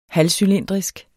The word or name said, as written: halvcylindrisk